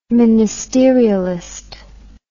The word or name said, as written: ministerialist